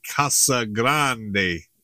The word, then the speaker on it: Casa Grande: I guess the proper pronunciation is Casa Grande.